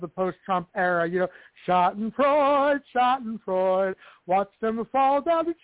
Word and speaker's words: Schadenfreude: We've got the, I can never pronounce the goddamn German word, express, Schadenfreude or whatever, however you pronounce that. – Schadenfreude. Yes, indeed.